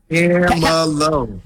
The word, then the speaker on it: Camelo: Camelo. Say it like this. Hold on. Do it with me. Ready? Camelo, baby.